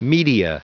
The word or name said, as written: media